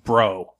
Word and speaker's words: Breault: His name is Henry, and his last name is spelled B-R-E-A-U-L-T, and it's pronounced Bro.